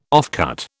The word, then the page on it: offcut: British English